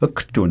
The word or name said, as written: ktun